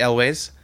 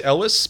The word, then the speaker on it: Elwes: Mine is by Cary Elwes. Is that how you say his name? – I always say Elwes. – Elwes? – I could be wrong.